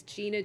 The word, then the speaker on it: Gina: my Twitter name is Gina Gemini, so it's spelled G-I-N-A-G-E-M-E-N-I.